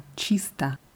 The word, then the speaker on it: чисто: Und ein Adverb ist chista, geschrieben mit O am Ende, aber ausgesprochen mit A, chista.